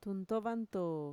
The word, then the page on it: tuntovantoo: Mixtec